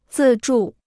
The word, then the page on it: 自助: zì zhù